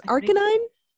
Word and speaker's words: Arcanine: It's ARC, so like ARC. Mm-hmm. ANINE. That's Arcanine? – I think that's how, well, in the show, the anime Pokemon, I think that's how it's pronounced. – Arcanine.